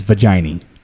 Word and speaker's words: vaginae: I've actually learned the proper pronunciation of the plural of vagina is vaginae. – Yeah. – I was saying vaginae or something like that. – Vagina, It's vaginae.